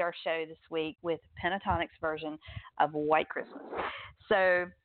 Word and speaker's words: Pentatonix: It's actually pronounced pentatonix. – Okay. – Pentatonix, but I think it's kind of like the first T is silent, so it's pentatonix, or at least that's how I've heard it pronounced.